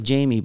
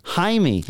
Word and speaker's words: Jaime: It looks like Jamie, but I happen to know this gentleman pronounces his name Jaime.